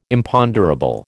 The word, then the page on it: Imponderable: American English